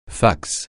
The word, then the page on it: fax: francais